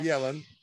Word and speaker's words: Джанет Йеллен: Итак, друзья, у нас Джанет Йеллен, если я, значит, с произношением, корректирую меня. – Джанет Йеллен.